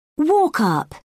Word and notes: walk-up: British English

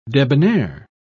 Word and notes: debonnaire: British English

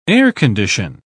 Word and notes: air-condition: British English

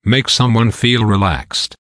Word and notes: make someone feel relaxed: American English

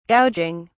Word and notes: gouging: British English

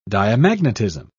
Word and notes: diamagnetism: British English

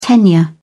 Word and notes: tenure: British English

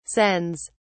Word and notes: sens: British English

